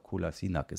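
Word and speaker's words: Kolašinac: Ähm, ein Satz noch, sie wollen wohl noch zwei, drei Leute holen, haben äh, Kolasinac oder Kolasinac oder Kolasinac, es gibt ja 100 Aussprachen von diesem Spieler, ich glaube Kolasinac ist richtig, ähm, geholt, ausgeliehen von Arsenal.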